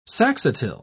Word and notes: saxatile: British English

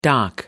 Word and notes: dock: American English

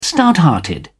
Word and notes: stouthearted: British English

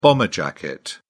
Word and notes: bomber jacket: British English

